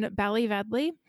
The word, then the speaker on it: Ballyvedley: And Bridget lived in Ballyvedley. I think that's how you pronounce it. It's spelled like Ballyvedlia, but when I heard it pronounced, it was Ballyvedley.